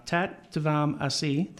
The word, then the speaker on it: Tat Tvam Asi: Tat Tvam Asi.